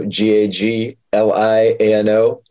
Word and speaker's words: Gagliano: uh, it's R I C O G A G L I A N O. There's a silent second G in there go Galliano, Gagliano.